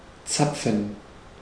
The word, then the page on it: zapfen: /ˈʦapfn̩/; /ˈtsapfɱ̩/; /ˈtsapfən/; /ˈt͡sa.p͡fən/